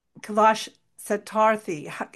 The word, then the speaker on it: Kailash Satyarthi: So your collaborations are amazing with these big public figures like Stuart Copeland, Kailash Satyarthi.